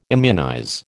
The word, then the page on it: immunize: American English